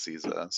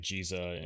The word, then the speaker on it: Ziza: And obviously Kiza, or am I saying that, is it Ziza? – I've seen it pronounced in the story on global as Ziza, so that's what I always say. – Ziza in global, it's like Jiza in Japan.